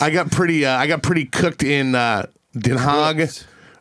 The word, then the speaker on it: Den Haag: Rotterdam, Amsterdam, pronounced the same, but like what it shows on the maps to Americans, it's the Hague is D-E-N-H-A-A-G over there, Den Haag.